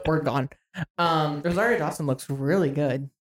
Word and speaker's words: Rosario Dawson: Rosario Dawson.